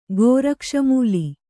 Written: ಗೋರಕ್ಷಮೂಲಿ

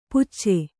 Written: ಪುಚ್ಚೆ

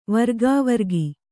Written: ವರ್ಗಾವರ್ಗಿ

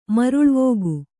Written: ಮರುಳ್ವೋಗು

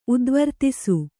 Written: ಉದ್ವರ್ತಿಸು

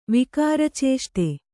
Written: ವಿಕಾರಚೇಷ್ಟೆ

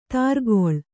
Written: ಧುರಗಲಿ

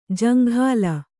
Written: ಜಂಘಾಲ

